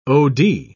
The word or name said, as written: od